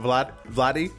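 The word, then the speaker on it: Vlade: It's like little Yachty, but Vladi, like Vladi Divac.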